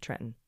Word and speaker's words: Trenton: They do the same with Trenton by not pronouncing the NT, Trenton.